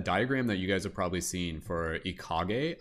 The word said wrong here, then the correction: Ikigai: I'm probably not pronouncing it right. Ikigai, the Japanese term